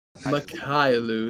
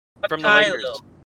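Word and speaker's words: Mykhailiuk: Mykhailiu. – Oh, Svi. Okay. – It's pronounced Svi Mykhailiu. Svi Mykhailiu.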